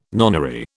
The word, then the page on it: nonary: British English